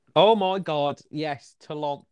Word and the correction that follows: Toulouse: Toulonce. Toulouse. I thought it's Toulouse. Toulouse.